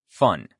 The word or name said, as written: fun